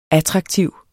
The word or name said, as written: attraktiv